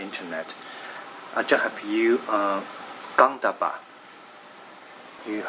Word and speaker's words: Gandhabba: G-A-N-D-H-A-B-B-A. – Yeah, Gandhava. – Gandhava.